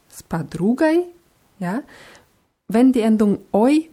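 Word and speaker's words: Spadrugai (с подругой): Bei spadrugai haben wir die Betonung auf dem u, deswegen wird oi so ein bisschen wie ei ausgesprochen.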